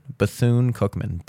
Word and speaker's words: Bethune-Cookman: And it looks like they want you to pronounce it Bethune, Bethune-Cookman, Bethune, which really just sounds like you're a dude with a lisp trying to say bassoon.